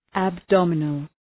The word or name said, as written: abdominal